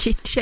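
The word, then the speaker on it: Tite: Chiche, relativt nya förbundskapten, har hittat det bästa av 2 världar i Brasilien. Den känslan har 5 plus för uttalet. Chiche är det alltså.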